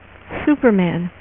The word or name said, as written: Superman